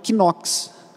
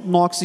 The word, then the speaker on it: Knox: É comum alguns irmãos pronunciarem John Knox, considerando a pronúncia do K. Mas é uma, uma pronúncia equivocada. É, a pronúncia é, é John Knox ignorando esse primeiro K.